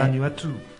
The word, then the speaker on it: Vanuatu: Vanuatu.